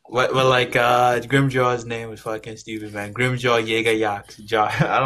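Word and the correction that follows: Jaegerjaquez: No, Grimmjow Jagger Jack or something. – But that's not how it's spelt. It's spelt Jaeger. But like, I guess you say Jagger.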